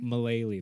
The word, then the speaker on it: Mullally: Megan Mullally. – Mullally, that's how you say it.